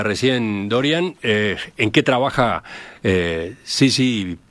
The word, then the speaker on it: syzygy: Syzygy. – Syzygy.